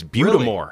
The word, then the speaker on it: Buttes des Morts: I'm going to say Lake Buttes des Morts. – That's actually pretty close. The S is silent, and it's almost like it's all one word. It's Butamore.